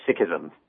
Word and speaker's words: Sikhism: Yes, it's actually Sikhism.